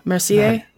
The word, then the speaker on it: Mercier: Is that the French way, Mercier? – That is, in fact, the French way.